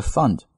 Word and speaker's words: fund: 为什么必须呢？To better fund NHS，在这里这个 fund 和前面的 foundation 两个意思，found foundation fund 一定要注意它的发音啊，foundation 里面是 ao 音，而 fund 是 a 这个音，所以短一些 foundation fund。